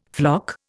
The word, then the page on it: floc: Indian